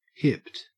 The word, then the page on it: hipped: Australian English